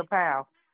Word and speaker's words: Powell: My name is Monica Powell. My name is Monica Powell.